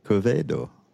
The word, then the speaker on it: Quevedo: And I'm with a new friend, and his name is Sylver Quevedo.